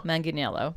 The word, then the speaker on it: Manganiello: This is Joe Manganiello.